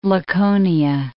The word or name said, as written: Laconia